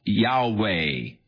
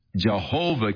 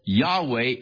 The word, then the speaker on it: Yahweh: So the general consensus of opinion is that the name was to be pronounced Yahweh. But somewhere along the line, the pronunciation of Jehovah came along, or Yehovah, and it has become more popular, but Yahweh is probably the correct pronunciation, though we do not know for sure. But